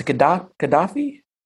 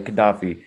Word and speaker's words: Gaddafi: So when speaking of it's a Gaddafi? – Yes. How do you explain? Okay, Gaddafi.